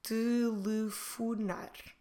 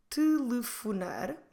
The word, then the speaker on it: telefonar: And the examples I want to teach you today, or to show you today, we'll use the verb telefonar. Telefonar means to call on the phone. Telefonar. Telefonar. Como se escreve telefonar? T-E-L-E-F-O-N-A-R.